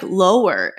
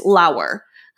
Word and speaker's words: Lauer: Rhymes with flower. – Okay. – So I'm like, okay. It looks like lower. And she goes, well, it's not. It's Lauer.